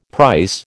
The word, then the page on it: price: American English